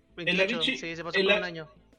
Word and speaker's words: Avicii: Ah, Avicii.